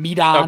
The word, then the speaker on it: Medon: Are we okay with the pronunciation, by the way? T-I-O-N-M-E-D-O-N. – Yeah, that's fine. Medon.